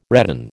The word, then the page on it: redden: American English